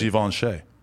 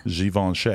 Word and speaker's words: Givenchy: Givenchy. – Okay. – Again, that's how fancy people say it. Givenchy.